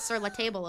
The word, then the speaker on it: Sur La Table: I always say, that's Sur La Table over there. – Oh. I don't. – Guys, it's spelled like table. It's Sur La Table.